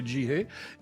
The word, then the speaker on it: Jihye: Jihe, ça s'écrit J-I-H-Y-E, je le précise parce que c'est pas évident de comprendre qu'il faut le prononcer Jihe.